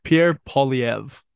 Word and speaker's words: Pierre Poilievre: Pierre Poivre? What's it? – Pierre Poilievre.